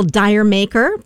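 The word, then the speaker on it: D'yer Mak'er: And on this day in 1973, they released the single Dire Maker from their Houses of the Holy album. Title was a play on the words, Did You Make Her?, being pronounced as Jamaica when spoken in an English accent.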